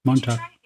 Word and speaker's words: Montauk: And we're going to talk about the Montauk Project tonight. Am I saying that right? – Yeah, Montauk.